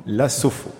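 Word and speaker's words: SoPHAU: C'est comme ça qu'on prononce ? – Exactement. – La Sopho.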